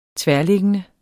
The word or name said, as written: tværliggende